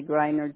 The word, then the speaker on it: Greiner: Okay, so I want to make sure I got the spelling of your name, A-M-E-L-I-A. – Yes. – And then Grenier. Is that what, Grenier? – Well, I say Griner, G-R-E-I-N-E-R.